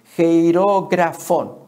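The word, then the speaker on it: Jeirografón (χειρόγραφον): Jeirografón es como se pronuncia.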